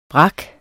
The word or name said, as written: brak